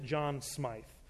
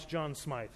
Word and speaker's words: Smyth: And usually the person there will look at you and go, we didn't have a John Smith here, but we have a John Smyth. So we pronounced it John Smyth. So in case you're having dinner conversation and you want to sort of show off, it's John Smyth.